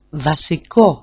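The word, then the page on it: βασικό: vasiko-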